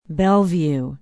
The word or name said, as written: bellevue